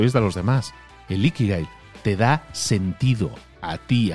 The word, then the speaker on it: ikigai: Y la regla número 10 es que sigas tu ikigai. No sé si lo digo bien, creo que es ikigai, ¿no? Creo que es con acento en la primera i, digamos, con inflexión en la primera i.